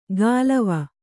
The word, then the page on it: ಗಾಲವ: gālava